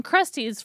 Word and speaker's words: Krusteaz: And Krusty's, for you listeners, it's spelled Krusteaz.